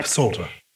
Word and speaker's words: psalm: Why is the P silent? – We did used to pronounce it. The PS combination is always found in Greek, so you'll find Psalter as well, S-A-L-T-E-R, but it was pronounced much like the... – Psalter. – The K and knife and knitting and things. Yeah. But because of the way that we struggle with some sound combinations, we tend to drop what doesn't sound natural.